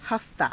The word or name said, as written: haftaa